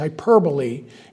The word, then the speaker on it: hyperbole: It's spelled hyperbole. But I had this teacher that would not ever let us pronounce it wrong, and so it's forever embedded in my brain. It's hyperbole.